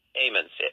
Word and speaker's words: Ammons: I mean, here you are, a priest, and you get a call apparently from is it the Amens family? Is that correct? Ammons. Ammons. Okay, Ammons family. That's a long A.